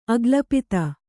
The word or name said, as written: ಅಕಲ್ಪಿತ